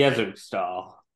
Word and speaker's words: Yezurkstal: Author of The Rise of Yes Sterkel. Did I pronounce that correctly or no? You're laughing. – Yezerkstahl. That was yes.